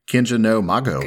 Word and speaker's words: Kenja no Mago: So I am totally down to transition over to our actual topic of the day, which is Wise Man's Grandchild, or Japanese pronunciation, Kenja no Mago. – Good job. Good job on that pronunciation.